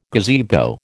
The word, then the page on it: gazebo: American English